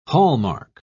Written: hallmark

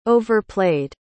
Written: overplayed

